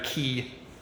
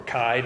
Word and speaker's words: chi: The $3 theological word for this is sometimes a chiasm, after the Greek letter chi, or chi, depending on how you pronounce it, obviously.